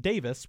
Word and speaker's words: Davies: For example, the film director Terrence Davis, his last name is spelled Davies, D-A-V-I-E-S, to Americans, right? But it's pronounced Davis, which the guys from the Kinks, they also pronounce their last name Davis because they're British, right?